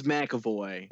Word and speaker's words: McAvoy: McAvoy? – James McAvoy.